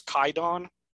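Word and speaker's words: Kwaidan: Now, the word Kwaidon is actually a more archaic word now. I guess the more modern word is Kaidon. But it's the same meaning. It's just they drop the W out of the pronunciation.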